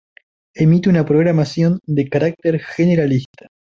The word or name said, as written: programación